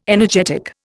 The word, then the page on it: energetic: Indian